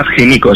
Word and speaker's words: Himiko: Himiko, Himiko. – Sí, sí, bueno, en español más Himiko, sí, podemos pronunciarlo así.